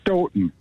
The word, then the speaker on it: Stoughton: You actually, the second time you said it right, Stoughton. They pronounce okay. it Stoughton. – Like Houghton, well, Michigan.